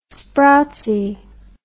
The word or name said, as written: sproutsy